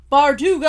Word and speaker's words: Bardugo: Ever since I figure out how to say it, I'm like Bardugo.